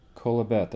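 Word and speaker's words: Qoheleth: Kolabet, that's how it's pronounced, kolabet. It's spelled Q-O-L-E-H-E-T-H, but it's pronounced kolabet.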